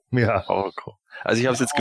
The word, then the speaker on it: Orko: Deswegen habe ich ihn auch so wie im Hörspiel ausgesprochen. Orko.